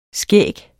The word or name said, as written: skæg